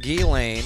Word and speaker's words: Ghislaine: Ghislaine. – Say it right. – Got the S is silent in there.